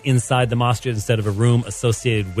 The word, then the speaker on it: Masjid: How do I say that correctly? Masjid? – Yes. Masjid.